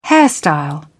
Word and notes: hairstyle: British English

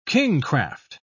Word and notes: kingcraft: British English

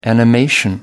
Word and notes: animation: American English

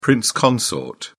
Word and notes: prince consort: British English

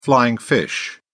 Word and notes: flying fish: British English

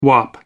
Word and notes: wop: American English

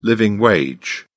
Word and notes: living wage: British English

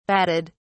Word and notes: batted: British English

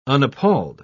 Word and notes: unappalled: British English

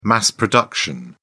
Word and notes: mass production: British English

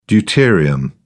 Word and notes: deuterium: British English

